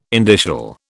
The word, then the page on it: indicial: British English